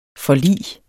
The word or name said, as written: forlig